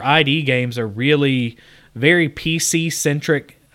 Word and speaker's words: id: It's just pronounced id. – Whatever. Is it id? – Yeah.